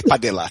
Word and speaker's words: Padilla: Estoy presumiendo que lo pronuncia Padilla porque, come on. Es Padilla, estoy seguro que es Padilla.